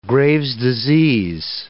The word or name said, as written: Graves' disease